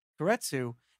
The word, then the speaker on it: Keiretsu: And um, what was really cool when we were doing the research was you're also managing partner at Keiretsu. Am I am I pronouncing that right? – You got it just and right.